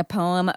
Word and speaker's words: poem: How do you say it? – Poem. – Poem? Like one syllable? – Yeah.